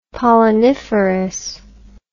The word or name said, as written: polleniferous